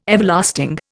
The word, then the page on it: Everlasting: Indian